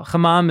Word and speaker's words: chamam: Well, chamam is the verb, and then the noun is more common, chema.